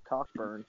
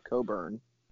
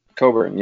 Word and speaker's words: Cockburn: Kofi, hashtag not Cockburn, Coburn. – Coburn, yep. Don't pronounce his name in a funny sexual way.